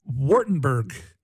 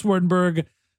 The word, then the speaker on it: Vordenberg: I pronounce it in German. It is Vordenberg, which is very hard. And I know in English, it sounds quite different. – Vordenberg. Boris Vordenberg.